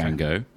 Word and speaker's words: Django: I believe that's how it's pronounced because, all right, I've got a working theory here. Now, correct me if I'm wrong, okay. Django. – Right.